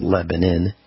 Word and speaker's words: Lebanon: So at this facility, which was in Lebanon, Missouri, spelled Lebanon, pronounced Lebanon, the same location where we had our Get Prepared Expo 3 times, 46,000 square foot facility.